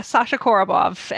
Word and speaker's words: Sasha Korobov: Sasha Korobov, and I am happy Korobov.